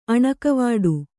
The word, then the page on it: ಅಣಕವಾಡು: Kannada